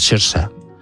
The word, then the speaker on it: Saoirse: Saoirse Ronan, que como es gaélico es Saoirse. Es como se pronuncia.